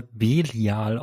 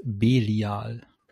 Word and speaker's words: Belial: Und ähm, daraufhin will halt dann Asmodi äh, Bilial, ist das richtig ausgesprochen? Du wirst das gleich nochmal eingehen, was ja, was dieser Name zu bedeuten hat, aber sage ich das richtig? – Ja, also eigentlich wird er Belial ausgesprochen, aber auch hier im Verlauf der Folge wird es mehrfach äh, unterschiedlich ausgesprochen. Aber eigentlich ja. korrekt ist Belial.